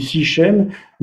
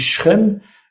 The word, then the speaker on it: Sichem: en français, on dit Sichem, mais en hébreu, on dit Shrem,